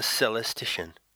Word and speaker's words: celestician: Well, yeah, I'm looking into celestics now, and my objective is to learn the method so that I can become a celestician. Now, there's a word that has never been said before. Celestician. C-E-L-E-S-T-I-C-I-A-N. You notice how it rhymes with technician.